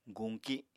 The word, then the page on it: guñki: apatani